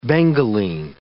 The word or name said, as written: bengaline